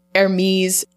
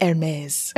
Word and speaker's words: Hermes: I want to say like the designer handbag, like Hermes. – I think it's Hermes.